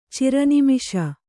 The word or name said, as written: ಚಿರನಿಮಿಷ